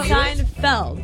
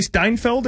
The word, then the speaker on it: Seinfeld: Like no, Seinfeld. What did I say? Seinfeld. – I was like, wait, it may be spelled like that. – Friends. I'll go Friends. – You don't want to say Steinfeld or whatever you said earlier? – Steinfeld.